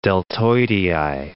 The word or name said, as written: deltoidei